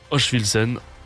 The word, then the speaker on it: Hochfilzen: Et toutes ces épreuves se tiendront évidemment à Hochfilzen en Autriche.